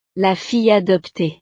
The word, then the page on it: la fille adoptee: French